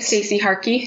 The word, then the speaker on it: Stacey Harkey: Stacey Harkey.